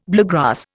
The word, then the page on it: bluegrass: Indian